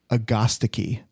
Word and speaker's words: agastache: We were trying to decide what the pronunciation of agastache is or hyssop. Agastici is how you say it.